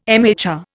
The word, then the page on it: MHR: Indian